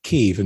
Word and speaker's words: Kyiv: As it turns out, pronouncing it Kyiv and spelling it K-Y-I-V is actually proper from the Ukrainian perspective. – Yeah. – Kyiv, K-I-E-V, that we've normally seen in the past, is in fact the same city, but that's using Russian pronunciation and spelling.